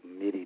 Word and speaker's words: MIDI: Wants to know if you like MIDI drums, M-I-D-I. Is that how you say it? – Yep, that's how you say it.